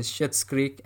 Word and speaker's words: Schitt's Creek: Russian Doll, Schitt's Creek. That's the correct pronunciation, Schitt's Creek, and Veep.